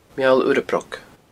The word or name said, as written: Meall Urra Broc